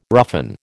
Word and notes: roughen: American English

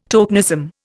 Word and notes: tokenism: Indian

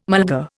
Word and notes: mulga: Indian

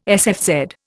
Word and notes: sfz: Indian

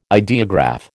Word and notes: ideograph: American English

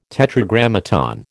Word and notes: Tetragrammaton: American English

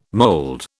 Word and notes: mold: British English

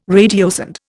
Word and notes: radiosonde: Indian

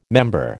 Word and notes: member: American English

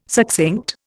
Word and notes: succinct: Indian

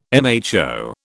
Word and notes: mho: British English

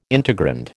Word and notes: integrand: American English

